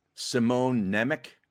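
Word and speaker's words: Šimon Nemec: Simone Nemec. – Yep. – Is that pronounced correctly? – Yeah, you've pronounced it right.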